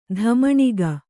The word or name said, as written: ಧಮಣಿಗ